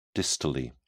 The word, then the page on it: distally: British English